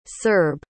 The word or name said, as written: serb